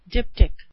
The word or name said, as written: diptych